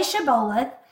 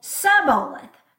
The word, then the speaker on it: Shibboleth: Obviously, I, as an American, am also not pronouncing this word correctly, but the gist of it is that they told them to say Shibboleth, and then they would say Sibboleth without the sh. So it wasn't sh, it was just s.